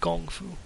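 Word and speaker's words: Gong Fu (功夫): It's actually pronounced Gong Fu.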